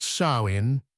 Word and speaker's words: Samhain: The ancient Celtic people of Ireland celebrated a festival called Samhain, pronounced Samhain, which created the rebellious dark spirit that still haunts Halloween today.